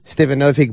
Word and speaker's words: Stefanovic: This Uber driver noticed that Peter Stefanovic, the nerdier brother of the two Stefanovic brothers. Am I saying that correctly? – Yes, you are.